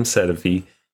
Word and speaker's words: Sedivy: So my last name, Sedefi, is a Czech name from that region. And it just means gray. And I'm sure the pronunciation is a bit different.